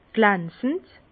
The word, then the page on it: glansend: Bas-Rhin; Herrlisheim